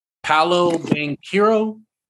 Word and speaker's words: Paolo Banchero: Paolo Banchero.